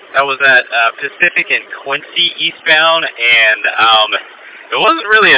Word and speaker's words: Quince: I think it's pronounced Quincy, Q-U-I-N-C-E.